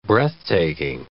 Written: breathtaking

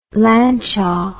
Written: Lancia

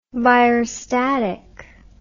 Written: virustatic

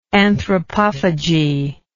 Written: anthropophagously

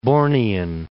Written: Bornean

